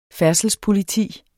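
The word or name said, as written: FÆRDSELSPOLITI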